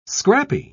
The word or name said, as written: scrappy